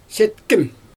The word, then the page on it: setkIm: Xyzyl